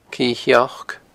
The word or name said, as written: Cidhe Sheoic